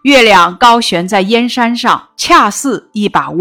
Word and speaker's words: 燕山: 大漠沙如雪，燕山月似钩。燕山指的是燕然山，在这里借指边塞。这是一个多音字，读燕的时候组词有燕国、燕京；读燕的时候组词有燕子、燕雀。